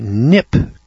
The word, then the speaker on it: Kneipp: Now, the following section on worms comes from an old book from 1896, which was entitled The Nip Cure. That's spelled K-N-E-I-P-P, cure.